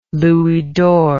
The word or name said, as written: louis d'or